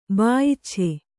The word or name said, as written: ಬಾಯಿಚ್ಛೆ